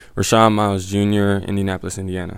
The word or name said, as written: Rashon Myles Jr.